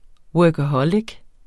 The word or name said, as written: workaholic